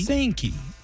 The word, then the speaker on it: Pysanky: Pasanki with a P? – Pasanki. – P-A-Z? – No, P-Y-S-A-N-K-Y. – Oh, yeah, the Y is silent.